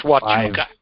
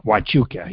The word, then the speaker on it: Huachuca: Now, they've done this before, but they say again this year, a military station on the East Coast and at Fort Hayayuca, HF station will conduct a high-powered broadcast on the 60-meter channel. – It's Huachuca. – Huachuca, yeah. Thank you.